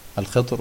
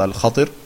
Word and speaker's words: Al-Khidr: Ja, Al-Khidr oder Al-Khatir. Zwei Aussprachen.